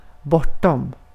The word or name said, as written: bortom